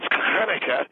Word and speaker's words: Hanukkah: I want you to, first of all, pronounce it right. It's Hanukkah, and it's...